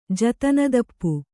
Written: ಜತನದಪ್ಪು

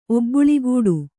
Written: ಒಬ್ಬುಳಿಗೂಡು

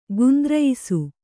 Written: ಗುಂದ್ರಯಿಸು

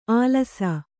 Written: ಆಲಸ

